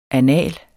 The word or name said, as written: anal